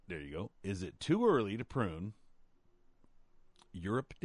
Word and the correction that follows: Euryops: daisies? – Euryops. – Euryops. Okay, she pronounced it a little bit different than I thought. It's spelled like Europe, right? – You can deadhead them.